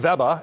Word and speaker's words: Weber: In Germany, consumers pronounce the brand Weber, and they are convinced it's a German-born and bred brand.